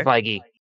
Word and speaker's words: Feige: Oh, Kevin Feige? – Yeah. – Okay. – Feige.